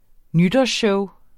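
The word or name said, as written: nytårsshowet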